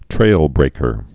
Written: trailbreakers

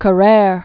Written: Carrere

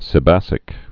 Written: sebacic acid

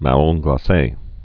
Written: marrons glaces

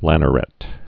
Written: lannerets

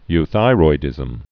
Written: euthyroidism

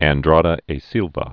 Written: Andrada e Silva

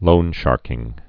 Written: loansharking

